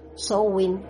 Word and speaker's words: Samhain: Se pronuncia Samhain a pesar de que se escribe Samhain y significa fin del verano y realmente es la festividad de los muertos.